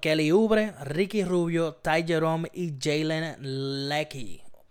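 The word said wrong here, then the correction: Lecque: Leque, se escribe Leque. Leque.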